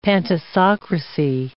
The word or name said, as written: pantisocracy